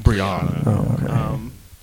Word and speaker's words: Breonna: It's spelled different, but it's Breonna. – It's like Bree. It's B-R-E-O. It's like, and then Anna.